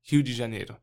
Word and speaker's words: Rio de Janeiro: And, so we moved from the state of São Paulo to Rio de Janeiro, and, let's pronounce it correctly, Rio de Janeiro, and moved there and pastored that church for a year.